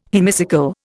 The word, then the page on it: hemicycle: Indian